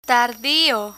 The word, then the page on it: tardio: Spanish